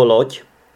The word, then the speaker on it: loď: Ale pak ještě existuje slovo loď. Loď, což se píše z dě, ale čte se to jako ť.